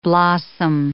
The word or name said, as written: blossom